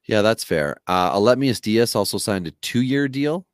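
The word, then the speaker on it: Aledmys Díaz: Well, I just think Aledmys Diaz is the new Jed Lowry.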